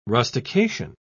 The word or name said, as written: rustication